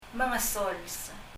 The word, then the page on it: mengesols: məŋəsɔls